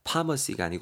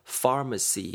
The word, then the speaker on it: pharmacy: pharmacy 발음할 때 여러분 주의하실 게 ph는 f로 발음해 주셔야 됩니다. pharmacy가 아니고 pharmacy 이렇게 발음하셔야 됩니다.